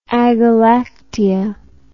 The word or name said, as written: agalactia